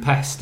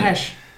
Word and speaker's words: Pest: Buda and Pest, isn't it? – Pesh. – Pesh. Pesh. – But why do they spell it Pest?